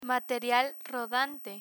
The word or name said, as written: material rodante